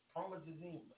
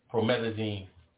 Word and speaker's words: promethazine: Promethazine. I'm not saying it right. Promethazine. – Promethazine.